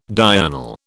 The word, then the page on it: diurnal: British English